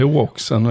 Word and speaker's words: Roxen: Ja, det är O-Oxen vet du. – Ja. det är inte ens ett R med i det då? Nej, det stavas ju V-O-U-X-E-N, Roxen.